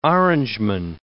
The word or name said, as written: Orangeman